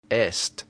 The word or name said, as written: est